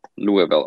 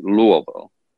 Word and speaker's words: Louisville: Then became more active with the organization in 2012 when we had convention in Louisville. Or I'm sorry, I should say it correctly, Louisville.